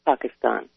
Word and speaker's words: Pakistan: It is Pakistan, actually, and it is Iraq. – I know. – It makes me nuts to hear people mispronounce it.